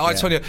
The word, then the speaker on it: I, Tonya: Yeah, I, Tonya.